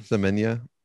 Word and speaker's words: Semenya: Semenya.